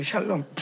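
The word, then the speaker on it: 샬롬 (שלום): 그 샬롬 그 미음자가 실제로 미음 발음이 나요.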